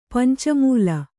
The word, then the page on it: ಪಂಚಾಮ್ಲ: pancāmla